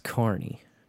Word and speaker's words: Kearney: Yeah, it looks like Kearney, but it's pronounced Kearney.